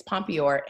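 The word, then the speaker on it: pompoir: It's like, it's Pompeii. – Mm-hmm. – Pompeor, I'm sorry. It's Pompeor.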